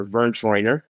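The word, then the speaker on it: Verne Troyer: One, for I can definitely remember Vern Troyer.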